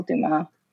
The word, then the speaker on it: Dumas: Mostly I'm also on Twitter, Ariel Dumas Ariel Dumas. The S is silent.